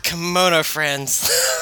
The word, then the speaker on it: Kemono Friends: God, is that how you say it? All right, it's Kemono Friends, right? – God, Kemono Friends.